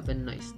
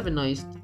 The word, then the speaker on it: Benoist: Melissa Benoist. Ok, sí, lo dije bien. Melissa Benoist eh, actualmente protagonista de Glee.